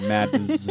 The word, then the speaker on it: Mads: I'm just going to call him Mads. I'm going to really pronounce that, dude. Mads.